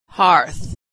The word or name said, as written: hearth